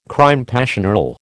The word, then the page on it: crime passionnel: American English